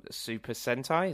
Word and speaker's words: Super Sentai: Super Sentai, is that mm-hmm.